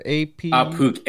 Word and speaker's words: Ahpook: Apuk, A-H-P-O-O-K.